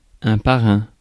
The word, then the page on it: parrain: France; [pa.ʁɛ̃]